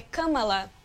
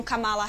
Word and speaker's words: Kamala: Boa, que eu também aprendi a pronúncia, Mari, é Kamala, né? Não Kamala Harris.